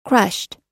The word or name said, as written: crushed